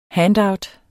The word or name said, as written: handout